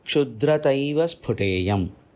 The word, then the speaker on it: Kshudrataiva Sphuteyam: When we pronounce it joining this, it goes like this. Kshudrataiva Sphuteyam.